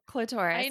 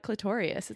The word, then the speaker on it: clitoris: Yeah, but I was saying clitorious. I was adding an extra syllable.